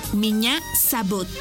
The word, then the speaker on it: Меня зовут: Y se escribe P-R-I-V-I-E-T. Привет. ¡Muy bien! Repite conmigo. Привет. ¡Muy bien! En ruso, me llamo se dice Меня зовут.